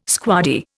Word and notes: squaddie: Indian

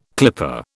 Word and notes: clipper: British English

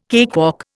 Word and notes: cakewalk: Indian

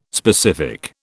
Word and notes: specific: British English

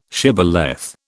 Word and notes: shibboleth: British English

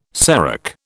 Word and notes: serac: British English

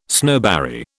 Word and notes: snowberry: British English

